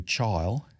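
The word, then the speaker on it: Chile: One is Voodoo Child, as sort of a Negro would pronounce it, Voodoo Child. It's written down as Chili, but it doesn't mean Chili. It's C-H-I-L-E, Voodoo Child.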